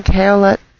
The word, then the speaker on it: Caoilte: He uses the name Kaolet Skytower in Second Life, which I'll just pronounce as it looks to make it easier for you to find him and his music. It's spelled C-A-O-I-L-T-E, Skytower. I know he pronounces it in the Celtic way, but he won't mind at all, if it helps you find his concerts easier.